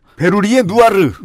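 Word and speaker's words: Bérurier Noir: 예를 들면은 베로리에 누아르라는 국어 표기법으로 치자면 베로리에 누아르라고 읽어야 되는데 좀 어색하네요. 어쨌든 그 유명한 라이브가 있는데 원래 어떻게 읽어요? – 베르리에 누아 베르리에 누아르.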